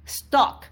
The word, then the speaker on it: stalk: porque estoquear no es lo mismo E-S-T-O-Q-U-E-A-R que stalking o stock, somebody, o sea, espiar a alguien, se escribe S-T-A-L-K, stock. Entonces se pronuncia prácticamente igual, pero no igual, no es igual, no, no, no, no, no, no, no, no es igual.